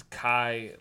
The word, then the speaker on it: Kai: I know I will not mispronounce his first name because it's Kai, like sky in the pie.